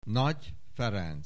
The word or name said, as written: Nagy Ferenc